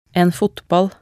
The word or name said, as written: en fotball